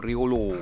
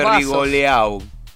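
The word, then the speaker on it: Rigolleau: El triptongo este francés se pronuncia Rigoló. – Rigoló. – Si no le dice Rigoleau.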